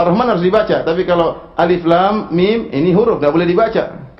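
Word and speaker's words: Alif, Lam, Mim (الم): Alif, Lam, Mim. Jangan dibaca alam. Keliru. Jangan dibaca alam, ya.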